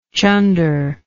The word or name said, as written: chunderous